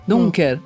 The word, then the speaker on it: Dunker: E obrigada, professor Christian Dunker. Dunker. Olha, pronunciei errado. – É, tá certo.